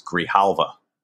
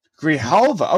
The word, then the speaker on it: Grijalva: I actually cheated on this and watched a video where they pronounced his name, and it's Grijalva. – Grijalva.